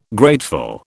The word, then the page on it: grateful: British English